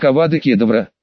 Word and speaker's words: Авада Кедавра: «Ну, смотри, заклинание звучит, как Авада Кедавра, ударение на вторые слоги.